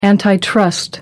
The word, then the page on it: antitrust: American English